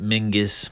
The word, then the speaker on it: MYNGVS: You pronounce it Mingus, but it's spelled with the V instead of the U.